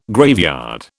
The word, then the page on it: graveyard: British English